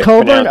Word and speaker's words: Cockburn: Well, that's how you pronounce it. It's spelled C-O-C-K. – Yeah, it is, and it's Colburn?